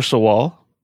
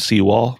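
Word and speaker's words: Sewall: Arthur Sawall. Mm-hmm. He was a shipbuilder, and I find it interesting. Like, I've heard people say his name's Seawall. My history teacher in high school said Seawall all the time, mm-hmm.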